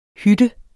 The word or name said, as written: hytte